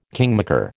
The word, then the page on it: kingmaker: American English